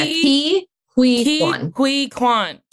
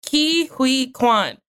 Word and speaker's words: Ke Huy Quan: The middle one was hard. I didn't know how to do hui. It's like really close hui. Together. But yeah, Ki Hui Kwan. – Ki Hui Kwan.